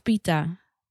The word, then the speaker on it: pitta: Zeg maar, jij zegt je bent kapha of pitta.